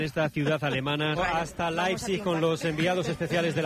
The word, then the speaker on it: Leipzig: Leipzig es lo que nos dijeron que que teníamos que decir.